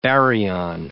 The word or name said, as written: baryon